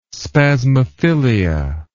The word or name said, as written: spasmophilia